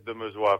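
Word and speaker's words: Demeusois: He pronunciado tu apellido un poco mal, lo siento. – Bueno, es francés, es Demesua, pero da igual.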